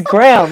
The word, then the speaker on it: Graham: you keep saying Graham. It's Graham, like a graham cracker.